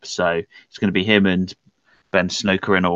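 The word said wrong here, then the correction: Skowronek: or however you pronounce his name. – Yeah, you've got Ben Skoronik. – Skoronik, yes, that's the one.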